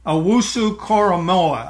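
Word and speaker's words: Owusu-Koramoah: Owusu Koramoah, I think, is the name of the player I'm thinking of. No, it is. And that was a very good pronunciation.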